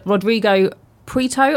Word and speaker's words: Rodrigo Prieto: But just as a little mention, Rodrigo Prieto, am I saying his name right? – Yes, sure.